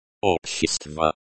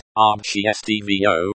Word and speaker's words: Общество: The stress falls on the first syllable. Общество is pronounced obchestvo, which is similar to the English obcvo.